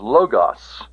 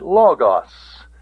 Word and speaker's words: logos: I've already mentioned people who pronounce logos as logos. Don't you do that now. You call it logos, and you'll be consistent. The omega is the O sound. Aw, logos is the aw sound.